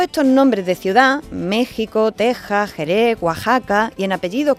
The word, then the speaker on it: Texas: Os cuento, la letra X no siempre se, se pronuncia como KS, ¿no? Como en extraño o exclusivo. Pues hay palabras en las que no representa ese sonido, que es el suyo actual, sino el de la J, como en México, que se escribe con X, y Texas, que se dice el estado de Texas, no de Tesa.